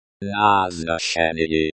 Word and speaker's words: назначение: The ch in назначение is like the ch in church. The nn at the end of назначение is pronounced similar to the nn in the English word funny.